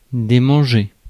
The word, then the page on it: démanger: France; /de.mɑ̃.ʒe/